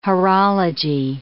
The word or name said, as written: horology